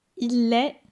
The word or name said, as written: Il est